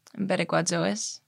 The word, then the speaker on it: Pereguazois: You may have to help me with pronunciation, but on the fourth track, Pereguazo? – Pereguazois. – Pereguazois? – Yeah.